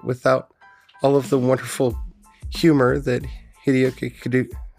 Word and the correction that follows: Kikuchi: Kikuchi brought to the work.